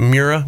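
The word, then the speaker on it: Miura: You had how do you pronounce it? Mura? – Yeah.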